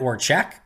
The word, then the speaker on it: Orzech: The one thing I did learn is that apparently Matt Orzech's name, the long snapper, is pronounced Matt Orchek.